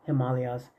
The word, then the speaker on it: Himalayas: David Wilcox says that it's pronounced Himalayas. Kind of like Brazil spelled with an S, but People spell it with a Z. It's Himalayas.